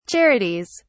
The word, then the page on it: charities: British English